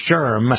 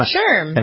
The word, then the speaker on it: SHRM: I've talked about them before on this program when I was trying to figure out how to pronounce SHRM. Remember that, Jay? I finally found out it was SHRM. – SHRM.